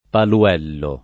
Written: Paluello

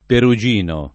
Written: perugino

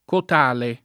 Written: cotale

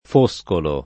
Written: Foscolo

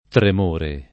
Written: tremore

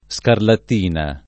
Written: scarlattina

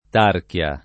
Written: tarchia

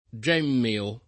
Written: gemmeo

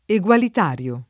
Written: egualitario

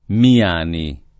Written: Miani

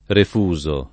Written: refuso

